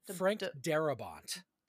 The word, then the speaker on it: Darabont: Frank Darabont.